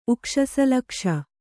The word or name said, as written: ಉಕ್ಷಸಲಕ್ಷ